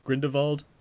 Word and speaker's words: Grindelwald: We're talking Widows and Fantastic Beasts, The Crimes of Grindelwald. Grindelwald? Am I saying that right? Is that right? – Grindelwald.